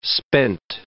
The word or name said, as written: spent